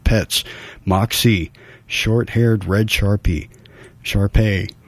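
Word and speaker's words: Shar-Pei: All right, we're looking for Moxie Lost, a short-haired red Sharpie mix. Sharpay, excuse my pronunciation there.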